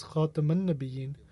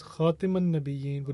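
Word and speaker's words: Khatam an-Nabiyyin: Despite the fact that both pronunciations are possible, however, I prefer for it to be pronounced as Khataman Nabiyin, because it means the seal of the prophets, whereas Khataman Nabiyin would mean the one who ends prophethood. Thus, teach my sons to pronounce it with a fatha above the letter ta.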